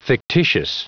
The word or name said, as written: fictitious